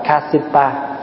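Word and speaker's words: Kāśyapa: Pháp Hòa nói ví dụ như ngài Ca Diếp, Ca Diếp là tiếng Phạn đọc là Kasipa.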